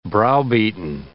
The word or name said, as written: browbeaten